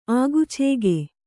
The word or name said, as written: ಆಗುಚೇಗೆ